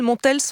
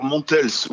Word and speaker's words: Montels: Montels, on prononce le S à la fin ? – Alors Montels, oui, Montels. C'est très important de prononcer toutes les lettres.